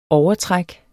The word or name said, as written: overtræk